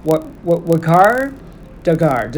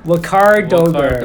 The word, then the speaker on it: Waqar Dogar: Okay, so I'm gonna get you to share with my audience, my friends here on Social Media Currency, how do I properly enunciate your name? – Sometimes I honestly feel like I can't even pronounce it right, but it is Waqar Dogar. – Waqar Dogar.